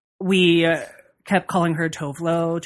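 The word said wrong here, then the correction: Tove Lo: Tovlo. – Right, Tavlo. – Tavlo. – Tavelo. – I found out that her name is actually pronounced Tuvalu.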